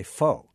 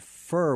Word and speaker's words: pho: in a lot of the names, and people just say pho, but that's not the correct pronunciation. It's actually more like the word fur without an R on the end, so fu.